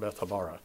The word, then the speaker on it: Bethabara: And he had been baptizing, he had been immersing, he had been submerging, we're told, beyond the Jordan, there in Bethabara. That's how you say it, Bethabara.